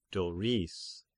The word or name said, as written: Doris